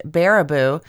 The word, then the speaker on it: Baraboo: My latest local to me legend, the paranormal pachyderms of Baraboo, Wisconsin, pronounced Baraboo.